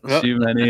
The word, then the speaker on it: Tchouaméni: Key player, I've got RJ's pronunciation in my head now. – It's the correct one. – Tchouaméni is out for Real, and he is a big miss in this kind of matchup.